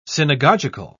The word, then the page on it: synagogical: British English